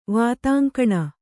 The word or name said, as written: ವಾತಾಂಕಣ